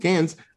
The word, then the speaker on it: Cannes: It was at Cannes? I don't think that's how you say it. – Yes, that's how you say it. – Cannes.